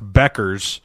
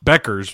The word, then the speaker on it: Bueckers: They ended up rotating 8 players total, but their leading scorer, oh my gosh, her last name is, I guess it's pronounced Beckers. But it's spelled B-U-E-C-K-E-R-S. But Beckers, 27 points.